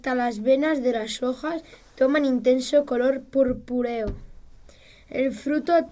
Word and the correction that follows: purpúreo: A ver, vamos a pronunciarlo bien. Purpúreo, purpúreo, a ver.